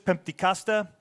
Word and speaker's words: Pentecosta: Their band name's also typically written in Greek, but pronouncing it in English, Pentecosta.